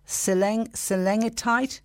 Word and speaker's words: Cilengitide: The study of the drug and it is pronounced Seleng, Cilengitide was carried out by researchers at the Royal College of Surgeons in Dublin.